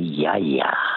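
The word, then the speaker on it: Yaya (γιαγιά): Well, in modern Greek, it's actually Yaya.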